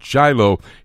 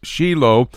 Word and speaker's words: Shiloh: You may pronounce it Shiloh here in America, but in Hebrew it's Shiloh, center part of the state, 3,500-year-old history as it relates to the Jewish people.